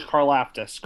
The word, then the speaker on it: Karlaftis: I have George Karlaftis. Is that how you say his name? – George Karlaftis, correct.